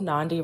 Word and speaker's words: Nandi: Nandi rhymes with Gandhi.